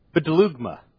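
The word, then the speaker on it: bedelugma (βδέλυγμα): It's bedelugma. Bedelugma. B-D, two consonants right next to each other. B-D, if you're going to write this in English, B-D-E-L-U-G-M-A. Bedelugma. That's the proper way to say it.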